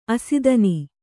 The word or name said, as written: ಆಸ್ಥಾನ